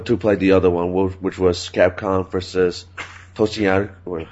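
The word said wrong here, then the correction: Tatsunoko: How do you say it? – Tatsunoku. – Tatsunoku All-Stars.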